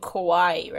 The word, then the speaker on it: kawaii: Oh, like or, oh, because you're thinking of kawaii, right? Is that how you pronounce it? Kawaii? – Yes, okay.